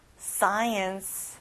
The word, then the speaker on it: science: Science. 앞에 SC로 돼 있지만 크 발음은 안 나요.